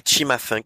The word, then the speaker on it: Cimafunk: Bah deuxième, je vous invite à écouter Chima Funk.